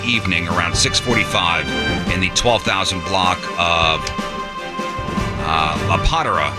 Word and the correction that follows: La Padera: Am I saying that right? P-A-D-E-R-A. – That's La Padera? – La Padera.